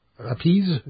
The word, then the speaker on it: apizza: Just like, you know, we went to New Haven to check out the pizza, or however you say it there. – Appease. – That's how you're supposed to say it? A-P-I-Z-Z-A. Is how they spell it.